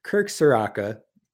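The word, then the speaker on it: Ciarrocca: I've been saying it wrong this whole time. I know I've watched him before, but you just see a name and your brain tells you to pronounce it one way. And if it's not right, your brain still sometimes tells you, just say it that way. So it's Kirk Soraka.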